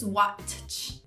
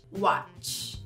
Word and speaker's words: watch: O T também é mudo na palavra watch. Não se diz watch, watch.